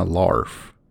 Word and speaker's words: Larf: and Only When I Larf. And uh, that's not a mispronunciation, that's the way it's spelled L A R F.